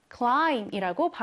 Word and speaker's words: climb: 자, 이때 마지막에 있는 B는 발음하지 않는 묵음입니다. 자, 발음하지 않는 발음을 우리가 묵음이라고 하는데요. 자, 그래서 climb가 아니라 climb이라고 발음을 해주시면 돼요. climb, 오르다. 한번 써볼게요. C-L-I-M, 그리고 B.